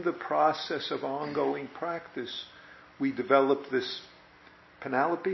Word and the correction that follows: panoply: is that the word? I might be pronouncing it wrong. What? – Panoply.